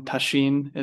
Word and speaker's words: Tasshin: and received the name Tasshin, or since you're in Japan, Tasshin is the Japanese pronunciation. I tend to sort of mispronounce it myself as an American, but it means reach truth or achieve truth or achieve wisdom.